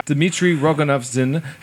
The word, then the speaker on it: Rogozin: Dmitry Rogozin has called for leading powers to work together to develop a system to intercept objects falling from space.